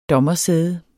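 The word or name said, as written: dommersæde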